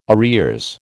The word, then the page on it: arrears: American English